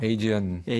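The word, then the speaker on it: Aegean: 그래서 자켓에도 그 그리스 바다가 이렇게 나와 있는 자켓인데, 근데 사실 이것도 영어로 발음하면 에게가 아니잖아요. – 에이지언, 에지언이 되잖아요.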